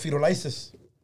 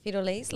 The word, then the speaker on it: Firulais: But when there's packs, los feto lices. – Oh. – What? – You learn something new every day. – It's spelled F-I-R-U-L-A-I-S. – Feto lace? Like feto lace? – No. Feto lice, L-A-I-S.